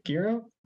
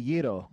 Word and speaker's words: gyro: Is it gyro? – It's actually Yiro.